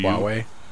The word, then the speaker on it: Huawei: Have you seen this, how do you pronounce it, Huawei? H-A-U? – Huawei.